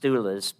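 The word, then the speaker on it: stulos (στῦλος): And the Greek word here is stulos or stylus. It's pronounced stulos, but it's actually spelled a lot like stylus, which you know what a stylus looks like.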